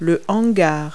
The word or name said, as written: hanger